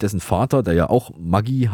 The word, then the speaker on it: Maggi: Wie müsste eigentlich die korrekte Aussprache der bekannten Speisewürze Maggi lauten? – Das ist nicht leicht. Das müsste eigentlich Maggi ausgesprochen werden, weil der Vater des Erfinders Julius Maggi Italiener war.